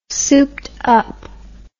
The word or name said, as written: souped up